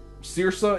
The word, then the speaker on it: Saoirse: What is that? – Saoirse. It's the Irish name, first name of an actress.